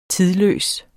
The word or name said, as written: tidløs